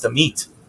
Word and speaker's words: Amit: I want everyone to know that my name is pronounced Amit. – Amit. – Yeah. Amit.